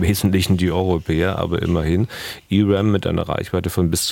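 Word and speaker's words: ERAM: Nun gab es dieser Tage die Meldung, dass die Amerikaner offenbar etwa 3500 E-RAM-Raketen, spricht man das so aus, Herr Bühler, E-RAM? – Genau. – Ja, E-RAM zur Verfügung stellen wollen.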